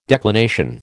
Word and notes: declination: American English

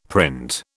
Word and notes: print: British English

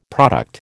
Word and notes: product: American English

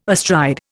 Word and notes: astride: Indian

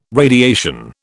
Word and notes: radiation: British English

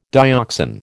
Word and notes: dioxan: American English

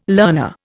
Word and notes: learner: Indian

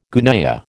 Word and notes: gunyah: American English

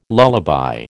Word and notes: lullaby: American English

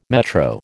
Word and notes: metro: American English

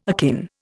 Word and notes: akin: Indian

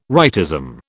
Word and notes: rightism: British English

